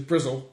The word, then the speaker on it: Bristol: Well, you can actually in proper Bristolian way, which is Brizzle, but, you know, we'll get onto that.